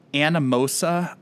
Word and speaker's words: Anamosa: 1891 in Anamosa, Iowa. I probably totally mispronounced that. – No, you did it. That's right.